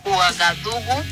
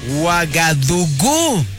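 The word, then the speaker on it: Uagadugú: Que no se escucha bien en la primera, pero es Uagadugu, vitamina C y crimen y castigo. – Uagadugu. Tienen acento en la U.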